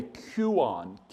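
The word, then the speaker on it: Kuon (κύων): One spoke of a mangy, vicious dog, and it was called a Kuon. K-U-O-N is how we would spell it in the English language.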